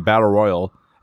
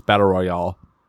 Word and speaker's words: Battle Royale: We talked about the Sci-Fi Christian Battle Royal. And this is something, or maybe somebody yelled at me for saying it wrong. It's Battle Royale. – Royale. Like you got two friends, Roy and Al.